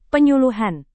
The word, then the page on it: penyuluhan: Indonesian